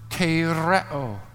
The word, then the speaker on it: tereo: It's the word tereo, T-E-R-E-O, long O, if you wanted to look that up.